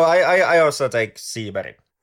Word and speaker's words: Seberg: So I believe the first name should be pronounced in an American way. And then the Swedish surname I'm just going to pronounce in the Swedish way. So Gene Seberg.